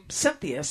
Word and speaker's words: Synthia: Type in Cynthia McIntosh in the search box and hit your enter button. My first name, Cynthia, spelled S-Y-N-T-H-I-A. And my last name, McIntosh, is spelled M-C-I-N-T-O-S-H.